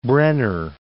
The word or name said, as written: Brenner Pass